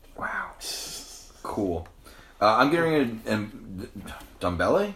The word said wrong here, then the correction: Ndombele: I believe it's Ndombele, I was giving it to you.